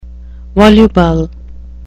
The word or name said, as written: voluble